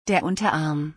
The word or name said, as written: der Unterarm